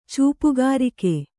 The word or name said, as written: ಚೂಪುಗಾರಿಕೆ